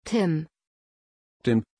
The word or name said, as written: Tim